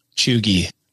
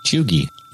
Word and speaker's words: cheugy: This word is spelled C-H-E-U-G-Y. – Chugy. – Yes. That's correct. – Chugy. – Yes.